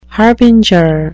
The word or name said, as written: harbinger